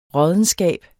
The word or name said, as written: råddenskab